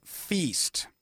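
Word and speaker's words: Pheast: It's called Feast, which is spelled P-H apostrophe E-A-S-T. So it's sort of a combination of Far East and Feast.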